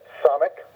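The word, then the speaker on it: Samekh: Lamed, mem, nun, then samek, two syllables again, so samek, ayin, two syllables again.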